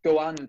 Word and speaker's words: Goan: They're called Goan Dogs or Goan Dogs. Uh, it's spelled G O A N, and dogs is spelled the fairy way.